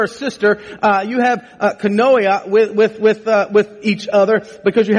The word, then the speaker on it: koinonia: It's koinonia, which means to hold something in common.